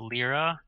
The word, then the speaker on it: lira: Okay, so Lyra. Okay, it's Lyra. Okay, perfect. So, Turkish Bitcoin volume soars as traders flee the Lyra.